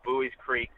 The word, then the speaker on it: Buies Creek: So Buies Creek, good to know.